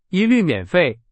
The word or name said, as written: 一律